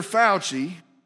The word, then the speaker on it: Fauci: There's not going to be a press conference where this Dr. Fauci,